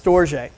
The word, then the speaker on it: Storge: So the second type of love that we see in here is a love that's pronounced Storge. So Storge is this familiar love.